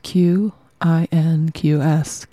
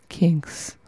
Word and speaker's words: Qinqs: Qinqs. I think is how you say it. Q I N Q S.